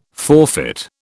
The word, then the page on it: forfeit: British English